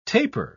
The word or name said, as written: tapir